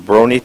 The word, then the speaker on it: Brony: And the closing film is a Brony Tale. Is that how you pronounce it? – Yep, that's right.